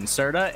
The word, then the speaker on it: Serda: Send me a screenshot of it on Twitter at Steven Serta, and we'll stop talking about the Panthers.